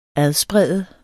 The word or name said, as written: adsprede